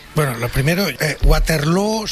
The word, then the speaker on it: Waterloo: Pero en Abba se decía Waterloo, ¿eh? – Okay. Abba sí, pero no es el referente lingüístico de los flamencos, ¿eh? – Pues no lo es, desde luego, pero ha hecho mucho daño en la pronunciación de Waterloo, que se pronuncia igual que Feijó.